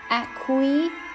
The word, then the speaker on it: acquiesce: 顺从 acquiesce。注意一下它读音里面这个后面两个字母 ce 的话放在那边简直就是摆设啊，因为它没有发音 acquiesce 来看一下关于这个单词的例句啊。